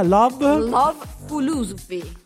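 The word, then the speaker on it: Love Foolosophy: Vi lascio con l'idolo per eccellenza, che era Jamiroquai con la sua Love Philosophy. No, non l'ho detto bene, come si dice? – Love Philosophy. – Ok, l'ho detto bene, Jamiroquai, a tra poco.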